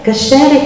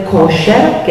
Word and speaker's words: kasher: Voi potete sentire la parola kasher, che è ebraico, oppure kosher, che è la versione Yiddish, americana, perché nella pronuncia ashkenazita talvolta la A diventa O e si ritrae l'accento.